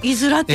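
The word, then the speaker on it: 五浦: はい、数字の 5 に、裏先とかの裏ですかね。 – 伊豆らって読むんですよね。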